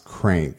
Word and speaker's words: Qrank: I want to say it's pronounced Crank, but it's spelled Q-R-A-N-K.